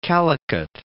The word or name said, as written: Calicut